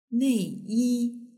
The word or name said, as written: neiyi